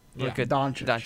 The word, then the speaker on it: Luka Doncic: I know Luka Doncic. Is that how you say his name? – Luka Doncic. – Luka Doncic.